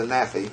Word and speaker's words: NAAFI: Yeah, as I say, so in Londonderry, you went to pay the Naffy.